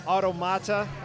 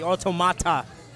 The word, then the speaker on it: Automata: Automata. Am I pronouncing that right? – That's very good. Yeah, I like how you enunciate the T, Automata. – Basically, the pronunciation, though, is correct.